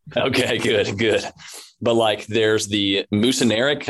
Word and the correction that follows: muscarinic: I think is how you pronounce it. Yeah, muscarinic. Yeah, yeah.